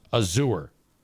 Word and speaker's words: Azure: Chris says that the name A-Z-U-R-E is pronounced Azure.